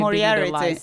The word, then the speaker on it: Moriarty: Alltså, nu kommer mitt dåliga engelska uttal fram, men Liane Moriarty. Hon som har skrivit Moriarty.